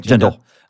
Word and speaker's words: Jindal: Jindal.